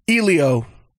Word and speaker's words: Elio: Is it Elio? – Elio.